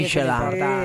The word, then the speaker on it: Michelin: Es francés, tiene por el Michelin. – Nada más que Michelin estamos acostumbrados... – O sea, decimos las Michelin. – Michelin. – La Michelin. – ¿Qué estamos hablando? – Es Michelin. Pero es Michelin, ella lo pronuncia bien en francés.